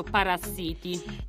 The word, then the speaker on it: Parasiti: Ci piacerebbe parlarvi anche di questo Parasiti, che si legge come paracittà in inglese, ma si legge proprio Parasiti.